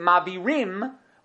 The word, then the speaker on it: Mavirim: It's written without a yud at the end, even though we pronounce it Mavirim, but it's written without the yud at the end, and therefore it's only referring to one person.